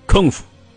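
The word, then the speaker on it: kung fu: 许多外文词典都出现了一个新词“ 功夫”，发音叫“ 空夫”。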